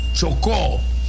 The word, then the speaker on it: Chocó: En el departamento del Chocó, acento en la O final, Chocó, costa Pacífico, a 96 kilómetros, indicó el Servicio Geológico Colombiano CGC en su primer boletín.